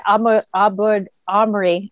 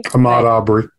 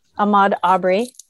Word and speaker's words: Ahmaud Arbery: I mean, look at Ahmaud Arbery. I'm saying his name wrong. I'm so sorry. – Ahmaud Arbery. – Ahmaud Arbery. Thank you.